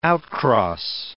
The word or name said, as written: outcross